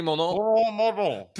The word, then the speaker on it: Mordaunt: It's absolutely not pronounced the French way. – Penny Mordaunt. – Yeah, Penny Mordaunt.